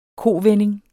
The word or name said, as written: kovending